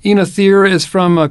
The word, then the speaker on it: Oenothera: Oenothera cespitosa. Some people say Oenothera. It's Oenothera cespitosa, no matter how you say it.